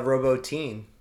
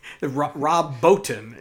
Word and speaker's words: Rob Bottin: But Paul Verhoeven with his Dutch accent does mispronounce some words and things, and he. Rob Bottin is the name of the guy who did the makeup and special effects designs on Total Recall.